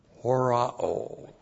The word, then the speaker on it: horao: H-O-S, relative pronoun host, whom. And then the verb horao. H-O-R-A long O. Horao.